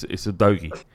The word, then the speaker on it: Udogie: It's Udogie. – All right. Udogie. – Yeah. That I'm telling you, and you can tell Windy and Nathan, that's how you pronounce it.